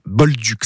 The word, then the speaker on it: bolduc: Il y avait peut-être ce qu'on appelle un boldu derrière, un bolduc, ça s'écrit B-O-L-D-U-C.